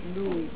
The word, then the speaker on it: Dewey: Los que más saben inglés dicen que se pronuncia Dewey.